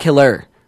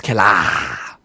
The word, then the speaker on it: Killa: It's also kind of cool just to say his name, Ghostface Killa, because it's not Killer. No, it's Killa, which is that much more exciting.